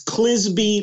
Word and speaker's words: Clizbe: It's Clizbe like Frisbee.